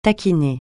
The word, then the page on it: taquiner: francais